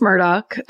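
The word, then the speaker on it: Murdaugh: It's pronounced Murdoch, I learned.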